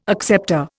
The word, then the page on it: acceptor: Indian